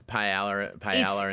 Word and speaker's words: paella: And how often is paella in the fucking how do I say that paella.